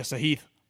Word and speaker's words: Sahith: Yeah, Sahith.